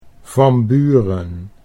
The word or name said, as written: Van Buren